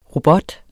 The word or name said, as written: ROBOTTER